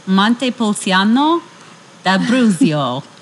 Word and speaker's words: Montepulciano d'Abruzzo: It's Montepulciano d'Abruzzo. That I know I'm saying right. – All right. – It's a 2016 Italian wine, and it just says, our Montepulciano d'Abruzzo is grown and bottled in this wonderful southern Italian region.